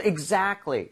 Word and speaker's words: exactly: So exactly 이렇게 얘기를 할 때 제가 발음하는 것만 들어보셔도 알겠지만 T 사운드가 거의 안 납니다. C하고 L이라는 자음 사이에 sandwich가 돼서 거의 안 나요.